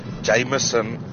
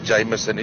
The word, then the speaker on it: Jameson: Dis 'n Alfa Romeo en 'n ou wat vir my sê gooi vir my 'n single Jameson in soda. Dit is nie Jameson nie, dis Jameson.